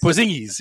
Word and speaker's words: Porzingis: No, how do you say it? – Porzingis. – Porzingis, yes.